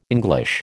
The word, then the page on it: English: American English